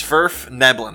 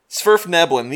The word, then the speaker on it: Svirfneblin: It's Svirfneblin. That's how you say the name.